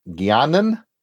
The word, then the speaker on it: Guiana: At the time, it was British Guianan. Am I it? It's spelled differently, so I imagine no, it's slightly it's pronounced the same.